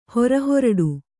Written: ಹೊರಹೊರಡು

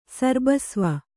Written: ಸರ್ಬಸ್ವ

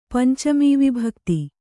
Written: ಪಂಚಮೀವಿಭಕ್ತಿ

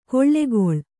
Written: ಕೊಳ್ಳೆಗೊಳ್

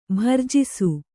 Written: ಭರ್ಜಿಸು

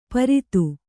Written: ಪರಿತು

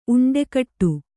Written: ಉಂಡೆಗಟ್ಟು

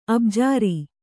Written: ಅಬ್ಜಾರಿ

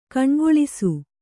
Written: ಕಣ್ಗೊಳಿಸು

